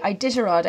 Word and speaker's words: Iditarod: We'll start there maybe and work a little bit back, because you have just come back from the Iditarod, I think is how you pronounce it. – That's how you say it. Well done. Good first pronunciation. It's a hard one.